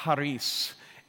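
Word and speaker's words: charis (χάρις): In the New Testament, the word for grace is actually pronounced charis, and you can't say it without making that awful noise.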